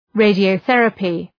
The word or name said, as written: radiotherapy